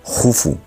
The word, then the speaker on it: Keops: Y eso lo aprovecha muy bien su hijo Keops, que por cierto, para cultura general, se escribe Keops, pero se pronuncia Jufu,